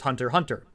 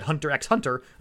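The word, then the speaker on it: Hunter × Hunter: The show Hunter Hunter, you don't pronounce the X. It's just Hunter Hunter. But there's an X in the middle. So you just, I always said Hunter X Hunter until someone corrected me.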